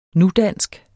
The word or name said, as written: nudansk